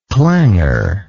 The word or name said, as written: clangorously